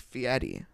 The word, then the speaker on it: Fieri: I believe it is Fieri.